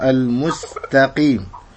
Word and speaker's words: المستقيم: كذلك التاء وانها من حرف حروف الترقيق المستقيم، ولا تقل المستقيم كما يقرأ بعض اخواننا الافارقة المستقيم كأنه ينطق التاء طاء.